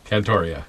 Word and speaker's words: Cantoria: Is it Cantoria or Cantoria? – Cantoria. – Cantoria. – Yeah.